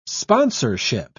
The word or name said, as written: sponsorship